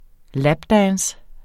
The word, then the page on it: lapdance: ˈlabˌdæːns